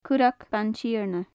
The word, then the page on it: currag baintighearna: gaelic